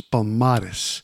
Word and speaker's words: Palmares: This song starts out with a Brazilian chant about the Columbola leader Zumbi dos Palmeiras. Excuse me. Zumbi dos Palmeiras. I got to say it right. The Quilombolo leader Zumbi dos Palmares.